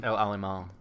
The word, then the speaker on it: El Alamein: El Alamein.